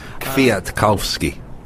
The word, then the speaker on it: Kwiatkowski: All about Mark Cavendish and then Mikko Kwiatkowski when the race gets into the hills. – Kwiatkowski. – Yeah, thank you. Thank you. You're our native Polish speaker, Richard Moore, there, correcting my pronunciation.